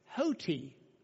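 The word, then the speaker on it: hoti (ὅτι): But that little comma on top makes an H sound. So you would pronounce this hoti, hoti, hoti, hoti, hoti, Hoti means, in this sense, because.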